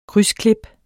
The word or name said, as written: krydsklip